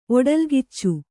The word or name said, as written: ಒಡಲ್ಗಿಚ್ಚು